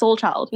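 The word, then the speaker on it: Slchld: So it's S-L-C-H-L-D. – Mm. – So he took out all the vowels. – Yeah. – But it's pronounced Soulchild.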